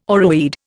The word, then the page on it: oreweed: Indian